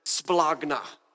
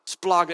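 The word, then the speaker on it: splagna (σπλάγχνα): And if you kind of say it like you're a little bit angry and sick in your stomach, it's better like splagna. It's like splagna. What does that mean?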